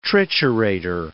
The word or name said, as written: triturator